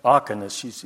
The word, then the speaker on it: diakonos: The Greek word of the early church that they used, and I want to ask the pastor about this, is it pronounced diakonos? Diakonos, you call it. Diakonos, okay. Okay. But the syllable, well, the second syllable is where the accent is.